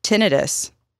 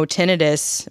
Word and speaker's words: tinnitus: And not only did the sensation not change, but I developed tinnitus from that day on. And for those of you who don't know, tinnitus or tinnitus, it can be pronounced both ways, is a ringing in your ear.